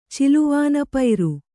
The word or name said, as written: ಚಿಲುವಾನಪೈರು